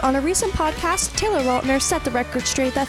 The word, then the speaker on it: Lautner: It is technically Lautner.